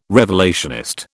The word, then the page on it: revelationist: British English